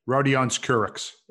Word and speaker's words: Rodions Kurucs: Houston will receive Victor Oladipo, Dante Exum, Rod- how do I say this? – Rodions Kurucs. – There you go.